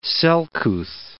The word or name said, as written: selcouth